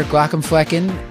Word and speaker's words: Glaucomflecken: Thank you so much for all of your wisdom, Dr. Glaucomflecken. And I'm just trying to show off now that I know how to say the name, which feels great.